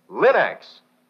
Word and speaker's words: Linx: Remember, Linex is spelled L-I-N-X, Linex.